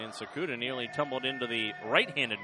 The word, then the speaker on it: Tsukada: Sakuda's name spelled T-S-U-K-A-D-A, but pronounced Sakuda.